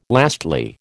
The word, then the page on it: lastly: American English